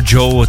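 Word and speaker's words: Djo: Jsem si říkal, proč si nechal takovéhle jméno, ono je vlastně srandovní, když se to píše D-J-O a čte se to vlastně jako Joe,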